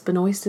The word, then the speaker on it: Benoist: Benoist is apparently how you say it, by the way. – I was saying Benoist.